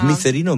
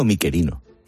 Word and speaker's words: Micerino: ¿Es Micerino o Miquerino? – Se puede pronunciar de las dos maneras.